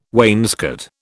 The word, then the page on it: wainscot: British English